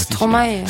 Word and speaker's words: Stromae: Wie spricht man den eigentlich aus? – Stromae. – Stromae. Stromae. Okay.